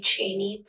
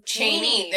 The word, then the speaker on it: Chaney: What's really cool is that the actor Lon Chaney, I want to say this is his last name. I hope I'm pronouncing that right. – I think most people say Chaney. – Chaney.